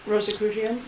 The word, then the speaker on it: Rosicrucians: Rosicrucians? – Yes, thank you.